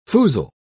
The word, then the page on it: foozle: British English